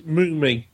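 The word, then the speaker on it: Mumy: It's Mummy.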